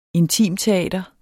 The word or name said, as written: intimteater